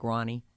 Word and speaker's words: Rani: R-A-N-I. Pronounced like Ronnie.